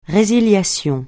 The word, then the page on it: résiliation: francais